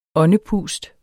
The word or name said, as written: åndepust